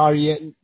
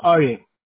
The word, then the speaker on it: Ari: My name is Arye. – Arye? – No, Arye. Just Arye. – I'm sorry about that. How do you spell that? – A-R-I. – Oh, Ari. Oh, okay.